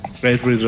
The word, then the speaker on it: Red Ring: Resulta que cuando se me dañó la Xbox por el maldito Red Ring. ¿Sí se dice así, sí? – Sí. Ok, gracias. – Red Ring, Red.